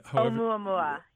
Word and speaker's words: Oumuamua: And then there was that weird object that entered our solar system, Oumuamua. – Oumuamua, yeah. – Thank you for pronouncing it, because I never was sure how you do it.